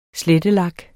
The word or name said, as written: slettelak